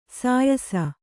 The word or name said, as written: ಸೀಯಿಸು